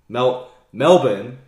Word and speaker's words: Melbourne: Melbourne, that's actually how it's pronounced, apparently.